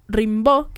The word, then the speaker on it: Rimbaud: Rimbaud, que esto lo tuve que buscar ayer cómo se pronunciaba, porque es francés.